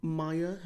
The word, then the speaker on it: Maja: It's dubious how old she is, but this girl, Maya, her name is. – Is it? – Yeah. Oh, it's spelled M-A-J-A.